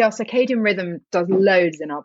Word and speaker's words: circadian: Circadian.